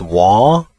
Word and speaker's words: Waw: The next one that we haven't read is Waw, W-A-W. And the way I pronounce it, I know, probably sounds horrible with my accent, but it's Waw, Waw, W-A-W.